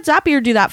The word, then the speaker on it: Zapier: And our sponsor today is Zapier. And yes, it rhymes with happier. And the best way I have found to describe Zapier to people is that it hooks your stuff to all your other stuff.